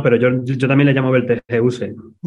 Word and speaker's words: Betelgeuse: Y nosotros, según la Fundeu Rae, dice que se pronuncia Betelgeuse, como suena, y que decir Betelgeuse o Betelgeuse es pronunciarlo a la inglesa y no tenemos por qué, porque tampoco no es una palabra inglesa, como has dicho muy bien, es árabe.